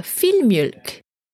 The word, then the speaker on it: filmjölk: Another breakfast, Swedish breakfast that I like and I do enjoy, which I cannot find it in Singapore, it's a filmjölk. Filmjölk. It's spelled F-I-L, filmjölk. M-J-O with two dots on the top, L-K. Mjölk. Fill milk.